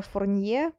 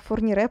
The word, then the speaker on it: Фурнире: И, кстати, только сейчас я поняла, что, возможно, называла Фурнире Фурнье, просто потому что я не знаю почему, но во многих французских сериалах есть герой по фамилии Фурнье, и теперь у меня эта фамилия на языке, я произношу ее только так. Ну, конечно, Фурнире правильно.